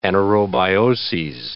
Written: anaerobioses

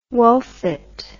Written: Wolfit

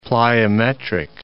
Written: plyometric

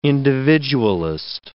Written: individualist

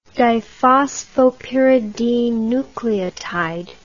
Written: diphosphopyridine nucleotide

